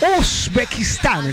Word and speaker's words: Uzbekistán: ¿Lo dije bien? ¿Qué tal mi pronunciación? – Excelente, así se dice. – Claro que Katy, que estaba estudiando, pues, a ver, Katy, ¿cómo sería Uzbekistán? – Así es.